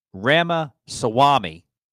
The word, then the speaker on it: Ramaswamy: Ramaswamy. I finally said it right.